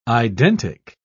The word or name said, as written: identic